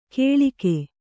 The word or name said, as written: ಕೇಳಿಗೆ